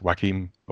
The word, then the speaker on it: Joaquin: And I'm pretty sure it is pronounced Joaquin. Are we all agreed on that? – Sure. – Yeah. I've been saying it completely wrong this whole time. I've been like, Joaquin. – So I realized, I just wanted to make sure of that, because I realized that some people might have like given that hard J.